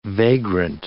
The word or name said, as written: vagrant